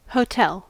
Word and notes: hotel: American English

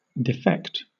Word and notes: defect: British English

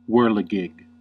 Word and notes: whirligig: American English

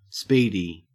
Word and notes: speedy: Australian English

